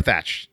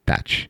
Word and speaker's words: Thache: Or Thatch, depending on how you... depending on where you're from. It's spelled T-H-A-C-H-E. But yeah. Thatch.